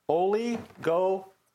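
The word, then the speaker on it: oligopistos (ὀλιγόπιστος): It is pronounced oligopistos. Oligopistos.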